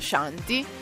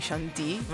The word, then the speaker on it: Shanti: Tutti i miei amici mi chiamano Shanti con l'eccezione di mio papà che può spostare l'accento e chiamarmi Shanti, ma solo lui.